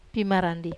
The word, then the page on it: pimarandi: wamesa